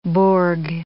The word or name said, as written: bourg